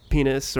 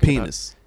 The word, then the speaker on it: Pinhas: Yeah, it's spelled P-I-N-H-A-S. – Richard Pinhas. – I looked it up. Apparently, it is pronounced something like Pinhas, not penis or Pinhas, as I kind of said in my head.